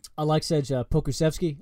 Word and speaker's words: Aleksej Pokusevski: I'm going to butcher this name. Aleksej Pokusevski. I hope I hit that right. – You didn't butcher it at all. You got that exactly right.